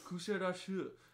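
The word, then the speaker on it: Kuşadası: So then the next day we went to Kusadasi, or it's Kusadashu. That's how the guy said it, and I was like, I can't say that.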